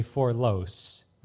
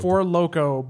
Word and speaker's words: Four Loko: Like the number four? – Yes. L-O-C-E. Forlos. That's literally how it's spelled. – Four Loko, but just missed a letter.